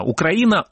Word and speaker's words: Украина: Украина, не Украина. Украина, украинцы. Запомните, это правильно по-русски.